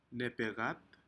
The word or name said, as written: nepekcat